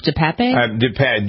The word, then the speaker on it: DePape: How do you say this guy's name, the intruder, David DePape? – DePape, D-E-P-A-P-E.